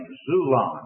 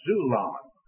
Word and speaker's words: xulon: There is another word that is used, and this other word is X-U-L-O-N. I'm giving you the English way of spelling it. From the Greek, X-U-L-O-N, Zulon. I presume that is the way to pronounce it, like xylophone. X-U-L-O-N, Zulon. That word, too, is brought over into the English, T-R-E-E.